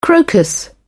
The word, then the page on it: crocus: British English